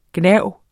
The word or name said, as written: gnav